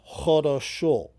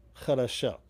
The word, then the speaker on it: хорошо: But in Siberia and in Ukraine, they pronounce it as a long O, like хорошо instead of хорошо, you know, which is good in Russian. But the O at the end is pronounced, so хорошо is a long O at the end, but everything, if you wouldn't know it, but all of those vowels are O's in that word.